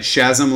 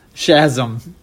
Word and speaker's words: Shazam: That's how you pronounce it, right? Shazam, like chasm? – Shazam.